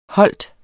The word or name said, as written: holdt